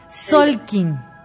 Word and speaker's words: Tzolkin: me encantó y bueno, además de todo, me gustaría que nos platicaras, claro, qué es el, a ver si lo estoy pronunciando bien, Solkin. – Así es, muy bien pronunciado.